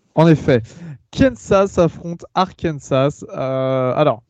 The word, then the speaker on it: Arkansas: Euh, non, Arkansas ou Arkansas Et Kansas ou Kansas On dit comment ah, euh, euh, euh, Arkansas, tu dis Arkansas, Arkansas, j'arrive jamais à le dire. ma, femme se, moque toujours de moi. Et Kansas, c'est Kansas. Mais Arkansas, en général, tu prononces pas le, dernier S d'habitude. Ça, permet de créer une différence, je suppose.